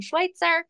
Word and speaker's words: Schwytzer: I'm under Jen Schwytzer. And I think the spelling of that is very strange, but it's S-C-H-W-Y-T-Z-E-R.